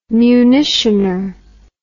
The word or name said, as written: munitioner